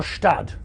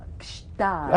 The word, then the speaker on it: Gstaad: Is it pronounced Gstaad or Stad? – No, Gstaad. – I see. Gstaad. – Yeah, yeah. Do it. – You can't just say Stad.